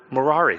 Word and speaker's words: Merari: There was the clan of Kohath, there was a clan of Gershom, and there was a clan of uh, Merari. It's like Ferrari, but with an M. That's how I remembered it. I was like, how do I say this word? Merari.